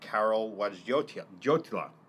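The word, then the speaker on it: Karol Wojtyła: fourth paragraph, he quotes someone named Carol Wojtyla. – Mm-hmm. That is how you pronounce it, yes.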